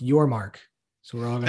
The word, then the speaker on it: Yormark: And I think Brett Yormack is the kind of guy that's going to get creative. First off, hold on. It's like, you got mad about mispronouncing. It's Yormark. – Yormark. Got it.